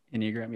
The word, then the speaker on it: Enneagram: it gave me more of an understanding that the Enneagram, that's how you say it? – Enneagram, yes.